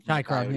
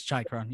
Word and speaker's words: Chychrun: Chychrun, yeah. It's Chychrun.